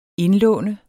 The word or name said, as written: indlåne